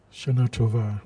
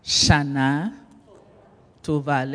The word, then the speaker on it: Shana Tova: Shana Tova. – Say it louder. – Shana Tova. – Shana Tova.